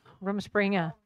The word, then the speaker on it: Rumspringa: Rumspringa.